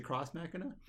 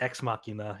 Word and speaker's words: X Machina: Is it cross Machina? – I think it's just X Machina.